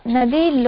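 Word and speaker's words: नदी: So Nadi long E. So Hari is a short E, but Nadi is long E.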